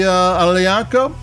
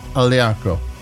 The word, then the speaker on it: Aglianico: And then we go to the Aglianico, and that's A-G-L-I-A-N-I-C-O. And there again, since it's a G-L, the G is silent,